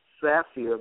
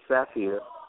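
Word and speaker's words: Saafir: The easiest way is to go on Google and Google search engine, enter my name, William Saphir. The last name is spelled S as in Sam, A-A-F as in Frank, I-R, William Saphir. It's two A's, one F, S-A-A-F-I-R. Just Google that, William Saphir, and when you, a lot will come up because we've been pretty busy.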